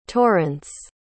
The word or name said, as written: torence